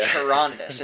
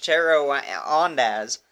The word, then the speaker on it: Charondas: No, I definitely pronounced that right. Yeah. Charondas. It's phonetic to American English. Charondas.